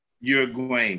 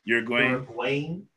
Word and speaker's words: Uruguayan: Uruguayan steakhouse. Uruguayan. Uh-huh. Uruguayan. That's good.